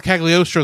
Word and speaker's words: Cagliostro: I love the animation because they do very well adjust to using the 1970s animation style from Lupin the Third, Castle of Castiel. How do you pronounce it? – Well, Castle Cagliostro is what you're trying to say, yes.